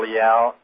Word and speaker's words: Leal: Or you can just Google Watchman and Nathan Leal, and it will come up. My last name is spelled L-E-A-L.